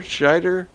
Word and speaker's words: Scheider: Scheider.